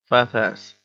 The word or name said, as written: fat-ass